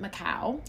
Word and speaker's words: Macau: She lives in, oh my God, I'm going to butcher it, Macau.